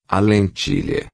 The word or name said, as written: lentilha